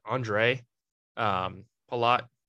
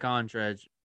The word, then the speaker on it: Andrej: I want to say it's like Andre Palat. I'm pretty sure it's close to Andre. – Oh, so it's like Andrej. Andre, but with a J.